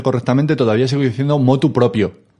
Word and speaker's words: motu proprio: Si tú, la mayoría de la gente sigue diciendo de motu propio, tú no les digas nada, pero cuando tú lo pronuncies, queridos oyentes, cuando lo pronunciéis, hacedme el favor, pronunciadlo bien, motu proprio, sin proposición.